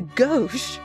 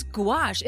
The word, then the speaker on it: gouache: Am I pronouncing that right? – I have no idea. How's it spelled? – G-O-U-A-C-H-E. – Gosh? – Gouache? – Gouache? – Sounds like goulash.